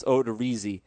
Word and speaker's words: Odorizzi: I've been saying his name wrong, and I've infected everyone else. Odorizzi, Kansas City and Toronto at home.